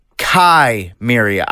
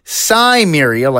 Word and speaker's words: Cimmeria: Now, I have always pronounced it Chimeria. I've been told it's supposed to be Cimmeria, like the real-life Sumerians who lived in ancient Mesopotamia, but I can't.